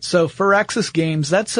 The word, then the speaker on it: Firaxis: Yeah, but it's pronounced Firaxis. Like fir tree.